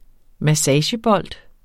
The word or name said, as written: massagebold